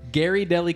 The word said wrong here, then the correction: Ghirardelli: Up until the other night, I didn't realize what the correct way to pronounce that brand is. It's Ghirardelli.